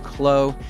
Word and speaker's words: KLLO: This is the band KLLO, and it's spelled K L L O, pronounced Klo.